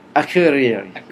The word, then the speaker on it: Akureyri: Akureyri.